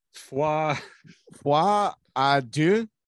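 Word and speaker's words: Folie à Deux: Foi adieu.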